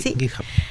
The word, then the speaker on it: GitHub: Sí, GitHub.